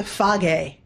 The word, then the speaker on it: Fage: You got to try the Phage. That's how you say it in Greek. Phage. F-A-G-E. – Is that F-A-G-E? – Yes.